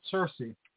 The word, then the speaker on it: Circe: In the Odyssey, Ulysses, he goes to this island, and I think it's Seers. Is it how you say her name? The sorceress? – But most people pronounce it Circe.